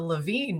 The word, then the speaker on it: Lavigne: But even, like, the Lavigne name, though, like, when you have, like, the silent G and stuff, too, like, that just kind of, like, it has, like, that seems pretty French to me.